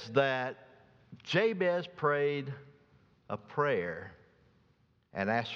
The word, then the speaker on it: Jabez: His name is pronounced Jabez. Jabez is the correct Hebrew pronunciation for him. But good southern colloquialism, and everybody I know calls him Jabez.